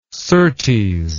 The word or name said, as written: surtees